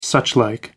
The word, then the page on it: suchlike: American English